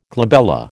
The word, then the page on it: glabella: American English